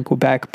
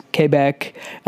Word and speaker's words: Quebec: Oh, and I guess before I go, for all you Quebecois out there, I know that in Canada it's pronounced Quebec. In America, we pronounce it Quebec.